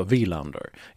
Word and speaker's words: Wilander: Och de flesta uttalade rätt, de betonade honom fel och sa Wilander istället för Wilander.